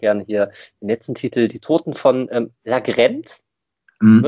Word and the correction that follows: Largent: Wird so ausgesprochen? Largent, ja.